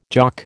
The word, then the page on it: Jock: American English